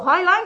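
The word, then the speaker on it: Hineline: Acuérdate, no pronuncies en tu cabeza porque cada uno aquí pronuncia el inglés de una manera y si yo digo Highline, que es como se pronuncia, igual tú no te enteras.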